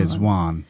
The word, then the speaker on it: Zwan: Yeah, Zwan.